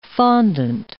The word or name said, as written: fondant